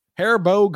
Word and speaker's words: Haribo: Haribo gummy bears and felt fat.